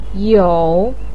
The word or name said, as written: you3